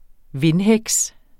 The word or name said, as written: vindheks